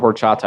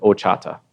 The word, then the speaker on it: horchata: Well, you know, Lucky O, when I first heard of horchata, I wasn't really pronouncing it right. I was calling it Ochata. – Okay. – And for the first three or 4 months, I was calling it Ochata, and I continually got corrected by people that knew how to pronounce it correctly.